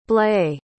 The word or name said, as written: blae